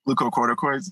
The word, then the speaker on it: glucocorticoids: How do you say that, pharmacist? – Glucocorticoids? – Yeah, there you go. Sounds so much better when you say it.